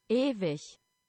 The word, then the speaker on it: Ewig: Ewig, E-W-I-G, which is Ewig. – Ewig. So yeah, not that I had that right. Very English, the way I pronounce that.